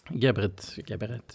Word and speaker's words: Geberit: Det är, jag vet inte om jag uttalar rätt, Geberit och Vestas Vind. Kan du berätta lite grann om caserna? Vad heter det? Heter det Geberit? – Geberit.